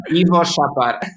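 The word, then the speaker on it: Iwo Szapar: Ivo Shapar.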